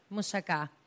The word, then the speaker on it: moussaka: Moussaka.